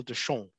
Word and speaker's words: Deschamps: And the reason is that the Australian ambassador to Cambodia was Noel Deschamps, D-E-S-C-H-A-M-P-S. You might pronounce it Deschamps, if you don't like the French accent.